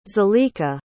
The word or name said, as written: Zalika